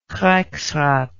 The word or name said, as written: reichsrat